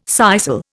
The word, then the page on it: sisal: Indian